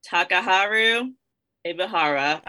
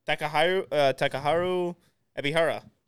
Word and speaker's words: Takaharu Ibihara: I think you nailed it. I mean, I think I'm looking at the name. I don't think you could pronounce it any differently. Takaharu Ibihara. Like, right? That's how it's spelled.